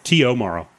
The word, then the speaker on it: T.O. Morrow: T.O. Morrow. – T.O. Morrow. – Yeah. Because his full name is Thomas Oscar Morrow.